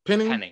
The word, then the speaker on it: Penning: I think that they go in the direction of Trevor Penning. Did I say his name right? – Penning. – Yep, that's his name.